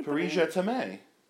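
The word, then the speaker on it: Paris, je t'aime: Paris Je T'aime. That's how I think it should be said.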